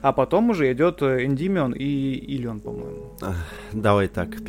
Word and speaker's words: Эндимион: Ты говоришь про Гиперион и переход э, к Эндименю. – Да. – Как Эндимион. – Эндимион. Эндимион, правильно, ударение, да.